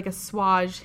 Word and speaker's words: assuage: I think it's a swage. Yeah, whatever. You're Canadian. It's fine. – That's fine. – A swage. – It sounds fancier. – It does. The French Canadian way to pronounce it.